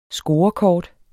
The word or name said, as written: scorekort